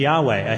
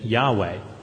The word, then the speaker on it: Yahweh: is a translation of the word which we sometimes pronounce Yahweh, a Hebrew word for the name of God, God's name. But we don't really know how to pronounce it,